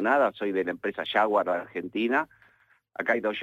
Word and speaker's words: Jaguar: A lo cual Frank Lowe le dice, lo primero que tenés que hacer es aprender a pronunciarlo. Jaguar es la ducha. Esto es Jaguar.